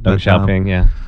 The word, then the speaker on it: Deng Xiaoping: Was Deng Xiaoping. I'm sorry, I apologize if I'm not saying his name right. – Deng Xiaoping, He is...